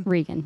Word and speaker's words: Rhegann: Rhegann, yes. – Yes, okay. – Because it's the H.